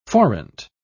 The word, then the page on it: forint: British English